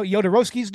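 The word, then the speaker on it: Jodorowsky: Josh Miller co-hosts a podcast with Steven Scarlatta, who is the producer of a documentary called Duratsky's Dune. I probably mispronounced that. – I know Yodorovsky's Dune.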